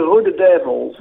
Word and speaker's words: Rode Duivels: The Rode Duivels.